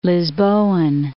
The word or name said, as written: Lisboan